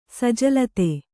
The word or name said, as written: ಸಜಲತೆ